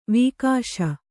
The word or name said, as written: ವಾಗೀಶ